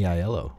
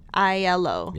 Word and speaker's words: Aiello: Danny Aiello. – Aiello.